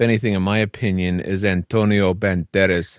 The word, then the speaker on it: Antonio: So to me, what would make this a classic is Antonio. And you have to say it like that. Antonio Banderas.